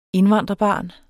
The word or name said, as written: indvandrerbarn